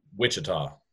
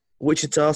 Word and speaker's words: Wichita: And you're in a place called Wichy, if I said that correct? – Wichita. – Wichita, sorry. I apologize for my poor pronunciation.